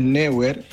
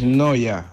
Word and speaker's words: Neuer: No es Neuer, es Noya.